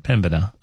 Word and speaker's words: Pembina: Pembina. Pembina. They phoneticized it out for me as well. Pembina. It's not Pembina. Don't do that. Pembina.